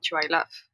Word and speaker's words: Chihuailaf: It's hard for people to pronounce, too. Well, so my Western name is Mandy Martini, but my Indigenous name is Kriyan Chuailuf, which is hard for people to pronounce, so... – I need to try.